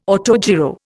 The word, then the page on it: autogyro: Indian